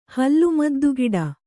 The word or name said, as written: ಹಲ್ಲುಮದ್ದುಗಿಡ